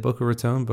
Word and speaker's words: Boca Raton: Is that how you say it? Boca Raton? – Boca Raton. Mouth of the rat.